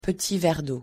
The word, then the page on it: petit verdot: French